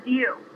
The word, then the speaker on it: Deu: Diu. – Giu? How do you spell it? – D-E-U.